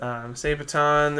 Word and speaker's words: Sabaton: Sabaton. How do you say it? – Sabaton.